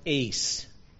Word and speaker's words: eis: The word is pronounced eis. It's spelled E-I-S.